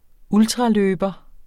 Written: ultra-

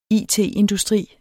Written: it-industri

